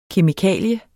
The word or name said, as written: kemikalie